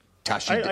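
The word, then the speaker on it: Tashi Delek: May I say, Tashi Dalek. – Tashi Dalek, thank you. – How is my pronunciation? – Tashi Dalek. I think we're good.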